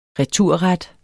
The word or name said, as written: returret